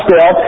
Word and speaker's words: El: El, pronounced El, occurs more than 200 times in the Old Testament, including these different compounds.